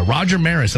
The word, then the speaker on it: Roger Maris: Roger Maris, am I saying that correctly? – Yeah, you did say that right.